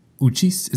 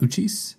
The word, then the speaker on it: Uchis: Uchis. Is that how you say it? Uchis? – Yeah.